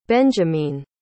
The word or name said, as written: benjamine